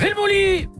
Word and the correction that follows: Helmut Fritz: Le chanteur s'appelle Helmut Fritz.